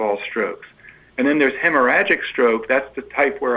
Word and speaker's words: hemorrhagic: I did have a text this morning that asked about, could you talk about hemorrhagic or strokes? Did I say that right? I know I didn't. – You did. Hemorrhagic.